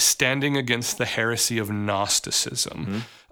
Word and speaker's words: Gnosticism: So let's start talking about this big, nasty, I almost said gnarly term, Gnosticism. – Well, gnarly I gotta have a silent G in there.